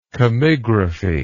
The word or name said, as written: chemigraphy